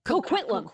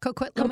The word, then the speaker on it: Coquitlam: I don't even know how to pronounce it, but Coquitlam. – Coquitlam. – Yeah, Coquitlam.